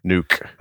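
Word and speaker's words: NUC: How you say it? Nuke? – Nuke.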